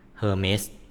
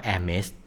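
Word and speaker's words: Hermès: Hermes นะครับแต่อ่านให้ถูกเขาอ่านว่า Hermes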